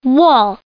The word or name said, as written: wall